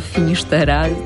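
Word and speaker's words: Finisterrae: Mogę wam polecić film Finistera, albo po katalońsku Finisztera. Trwają dyskusje, jak można byłoby wymawiać ten tytuł.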